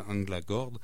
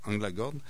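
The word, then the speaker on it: Änglagård: Et comme je le disais là, on a souvent tendance à dire Anglagard, mais il semble que le A avec le petit point sur le dessus, ça se prononce O, alors Anglagord.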